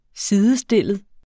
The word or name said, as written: sidestillet